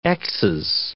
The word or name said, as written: xs